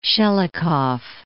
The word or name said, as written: Shelikof